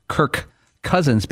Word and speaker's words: Kirk Cousins: wrong in the way you pronounce Kirk Cousins, because when you say it quickly, the last K of Kirk and the first C of Cousins are almost redundant.